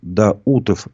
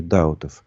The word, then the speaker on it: Даутов: А, тут говорят, что Рифат Даутов, а не Даутов. А, ну, извините, если я неправильно ударение поставил.